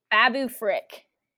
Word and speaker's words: Babu Frick: All right, next, choose a character from Rise of Skywalker: R2-D2, C-3PO, Claude, Dio, Bulio, Janna, BB-8, or Babu Frick. – Babu Frick. – Babu. Dang, I'm really putting the emphasis on the wrong Babu here. Naboo, Babu.